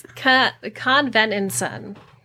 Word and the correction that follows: Convenenza: Nope. There are just too many syllables in this. Convenenza. Convenenza. There we go. – How do you spell it? – C-O-N-V-E-N-E-N-Z-A. Convenenza.